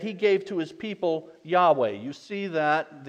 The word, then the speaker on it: Yahweh: L-O-R-D, all capitals, is the word Y-H-W-H. We pronounce Yahweh.